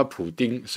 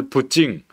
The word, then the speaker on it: 普京: 不敬，因为如果叫他普丁是用英文发音，那干脆叫他布丁算了哈。他的俄文发音其实是普京啊，比较像普京啊哈。